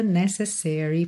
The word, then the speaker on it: necessary: A palavra necessary tem a sílaba tônica na primeira sílaba, necessary.